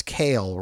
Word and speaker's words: Kael: It's Kale, right? Like the vegetable? That's the fearsome warrior? – Yes, but it's spelled K-A-E-L,